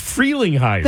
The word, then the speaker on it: Frelinghuysen: I can't believe it's free, not fre. It's Frelinghuysen. – Then why is there only one E? – That's just how it's pronounced. It's a long E. That's why.